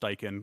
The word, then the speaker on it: Steichen: Also, last thing I'll say is that I'm very disappointed that his name is pronounced Steichen because I really wanted him to be Shane Stinken, but that's okay.